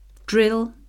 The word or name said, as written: drill